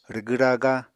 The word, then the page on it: rwgw-raga: Apatani